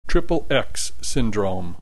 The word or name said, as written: XXX syndrome